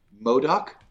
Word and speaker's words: MODOK: This week, we are going to talk about the Hulu exclusive MODOK.